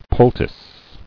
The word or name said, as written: Poultice